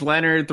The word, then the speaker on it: LNRD: My dude's name is Leonard 3.0, and it's spelled LNRD, and it stands for Lancaster Neural Reconnaissance Droid.